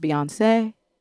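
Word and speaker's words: Beyond C'est: And when you say it, you don't pronounce a T and all that stuff. It's pronounced Beyonce.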